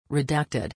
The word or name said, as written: redacted